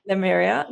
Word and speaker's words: Lemuria: Am I saying that right? – Lemuria.